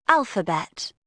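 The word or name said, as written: alphabet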